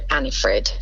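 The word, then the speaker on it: Anni-Frid: And the dark haired is called Annifred.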